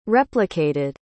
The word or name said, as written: replicated